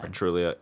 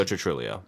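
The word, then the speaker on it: Truglio: Joe Artiglio, how do you say his name? – Yeah. – Yeah. The guy from Brooklyn Nine-Nine. – Trulio. Joe Trulio. That's it. – Trulio. Right.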